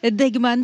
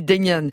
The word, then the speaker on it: Deignan: Deignan, sacrée après un raid solitaire de 80 km. – Merci Fanny Lechevestrier, puis pour la bonne prononciation. Donc la vainqueur de ce Paris-Roubaix, Lizzie Deignan.